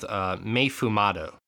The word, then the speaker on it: Mei Fumado: It is called Mei Fumado, and I'm pronouncing that in the American way, not the Japanese way.